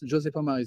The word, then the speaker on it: José Paumard: Yes, José Paumard is my Twitter handle. – So J-O-S-E underscore. – No underscore. – So J-O-S-E.